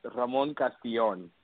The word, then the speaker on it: Ramon Castillon: It was close. I got to give you major kudos. It's Ramon the right way is Ramon Castillon.